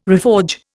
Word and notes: reforge: Indian

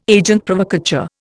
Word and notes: agent provocateur: Indian

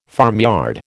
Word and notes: farmyard: American English

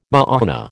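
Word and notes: mañana: American English

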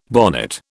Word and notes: bonnet: British English

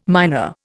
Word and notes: miner: Indian

